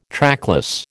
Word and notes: trackless: American English

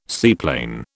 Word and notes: seaplane: British English

